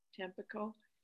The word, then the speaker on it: Tampico: It's T-A-M-P-I-C-O, Tampico.